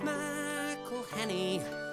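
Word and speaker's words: McElhenney: They think they recognize him from his big time TV show, but despite the accolades, despite the load of fame, one thing that they do not know is how to say his name. Pronouncing all those N's and E's and H's can perplex them. So here's a little birthday gift from all your mates in Wrexham. It's McElhenny, McElhenny.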